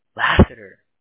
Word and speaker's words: Laseter: L-A-S-E-T-E-R. – How do you pronounce that? – Lassiter. – Lassiter.